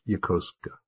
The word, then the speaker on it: Yokosuka: Yokosuka, Japan. And my producer came up to me and she said, Jamie, that was really good, except that it's pronounced Yokosuka. In Japanese, they don't accent it that way.